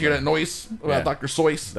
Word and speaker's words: Seuss: Apparently, it's Dr. Seuss. – Dr. Seuss. That's how you say it.